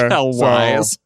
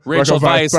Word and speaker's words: Rachel Weisz: Raquel Wise. Rachel Weisz is the English Rachel pronunciation.